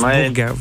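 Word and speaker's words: Burgas: Alors, Burga, on aurait pu l'accepter parce que tout simplement, ça s'écrit B-U, hein, mais ça se prononce Burga, voilà, tout simplement.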